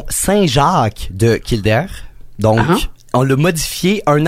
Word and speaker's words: Kildare: Kildare. La vraie prononciation, c'est Kildare.